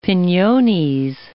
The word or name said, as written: pinones